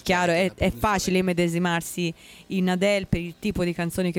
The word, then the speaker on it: Adele: C'è Adele, o Adil, come si pronuncia? – Adele.